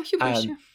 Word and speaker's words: hubris: Ted Farrow is basically Elon Musk and basically thought in his hubris. Hubris? I feel like I'm saying it wrong. – No, hubris. – Hubris.